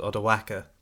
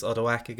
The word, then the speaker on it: Odoacer: So Zeno then gives Theodoric permission to attack Italy, and specifically this Odawaka guy. I think that's how you say it, isn't it? It might be Odoacer. Spelled O-D-O-A-C-E-R, and I'd say Odawaka.